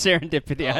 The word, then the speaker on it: Serendipity: So it's Sunday Fun Day with Southern Dippity. So you want to check that out. – Southern Dippity. – Yeah, Southern Dippity. Did I say it wrong? – You said it's Serendipity.